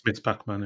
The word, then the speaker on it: Ms. Pac-Man: Yeah, it's Ms. Pac-Man. – Yes. – But pronounced Miss Pac-Man, yeah.